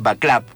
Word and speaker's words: Václav: No es una crítica al gobierno de Václav Havel, lo pronuncio como lo pronuncian los checos, porque es Václav, como se escribe, que es el hermoso escritor checoslovaco, un increíble humanista aparte.